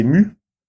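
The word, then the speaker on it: My: Donc en finlandais, c'est Mu. Donc ça s'écrit M-Y.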